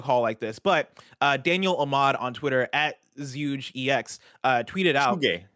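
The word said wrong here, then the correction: ZhugeEX: Juge EX, thank you.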